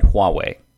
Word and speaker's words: Huawei: China then had Huawei. I hope I'm pronouncing that correctly. – Yes.